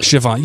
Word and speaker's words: Siobhan: Siobhan. Am I saying that right? – Siobhan. You still can't get, this is like 2 years in a row, you still can't get it right.